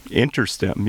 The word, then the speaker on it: InterStim: What's InterStim? Did I say that correctly? – InterStim, yes.